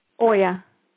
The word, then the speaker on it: Oya: Oya. – Oya. So when you talk about that, is it kind of like you're channeling Oya, or is it kind of like you're in touch with Oya and you say, okay, what's coming through from Oya is this?